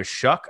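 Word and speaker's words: Shough: Probably the front runner from our minds, tell us if we're correct, is Tyler Shuck. I double and triple check to make sure that's actually how you pronounce it, from the Oregon Ducks.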